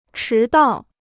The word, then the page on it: 迟到: chí dào